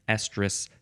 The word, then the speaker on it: estrus: And then right up until the next one starts, that's one estrus cycle. Estrus, that's how it's pronounced. And I think the next, the other one that's coming up is also pronounced the same way.